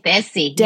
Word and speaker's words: Desi: Desi.